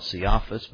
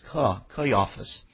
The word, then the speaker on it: Caiaphas: That is pronounced C-A-H-Y-A-F-U-S, Caiaphas. I used to want to call it Caiaphas, but it's Caiaphas, which was the high priest that same year.